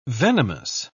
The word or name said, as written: venomous